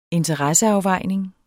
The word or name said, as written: interesseafvejning